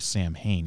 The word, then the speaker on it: Samhain: The tradition of Halloween originates from All Saints' Day, but it's most famously associated with the ancient Celtic festival of Samhain, which is spelled like Samhain, S-A-M-H-A-I-N, but it's actually pronounced Samhain.